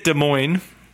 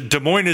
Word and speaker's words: Des Moines: Then we get Des Moines. – What? – Then Wiggum, Georgia. Des Moines is Caroline and Michael. – Isn't it pronounced Des Moines? – I'm sorry, yes. My apologies.